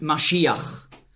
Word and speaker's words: Mashiach: In meiner Bibel steht Messias, weil im Grundtext hier das Wort Mashiach, so ist die hebräische Aussprache, zu finden ist. Messias ist eine sprachliche Anpassung ans Griechische. Nicht wahr? Im Griechischen konnte man den Sch-Laut nicht aussprechen.